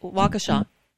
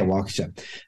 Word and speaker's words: Waukesha: Waukesha. – Yeah, Waukesha, of Milwaukee.